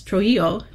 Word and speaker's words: Trujillo: We are pulling fragile historic music from cathedral archives across the Americas and the Codex Trujillo de Peru. I hope I pronounced that right. – No, you're great. The pronunciation is perfect.